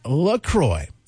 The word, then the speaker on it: LaCroix: Yeah, it's a cross between La Crosse, Wisconsin, where it's made, and the St. Croix River in western Wisconsin, officially pronounced LaCroix.